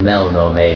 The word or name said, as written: Melnorme